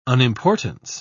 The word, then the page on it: unimportance: British English